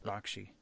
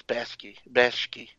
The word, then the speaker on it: Bakshi: And then the body of work that is, you know, Ralph Bakshi, I think it's pronounced Bakshi. – Bakshi? – Yeah. – I always thought it was Bashki.